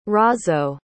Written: Razo

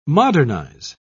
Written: modernize

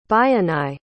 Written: bayanai

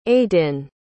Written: aydin

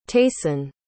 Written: tayson

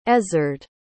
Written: ezzard

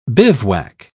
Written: bivouac

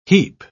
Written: heap

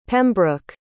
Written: pembroke

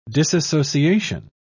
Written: disassociation

